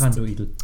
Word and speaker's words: Thranduil: Auf jeden Fall heißt. – Thranduil. – Oh Mann, heißt er Thranduil. Und Thranduil ah. ist der Vater von Legolas. – Ja, Thranduin. – nein, Thranduil mit L.